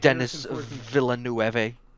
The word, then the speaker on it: Denis Villeneuve: It makes me even more upset that it made like no money, because fucking Danny Villeneuve, or however the fuck you pronounce his name, wanted Denis Villeneuve. – Yes, Denis Villeneuve.